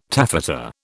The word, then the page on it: taffeta: British English